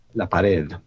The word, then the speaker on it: La Pared: La Pared.